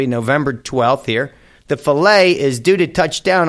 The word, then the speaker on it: Philae: Let me spell that for you. P-H-I-L-A-E. I'm calling it Philae. Like fish filet, huh?